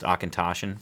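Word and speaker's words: Auchentoshan: We can lump that together with the is it Ashento Shan that yes. – I said that's actually pronounced Akintoshan. It's a Scotch.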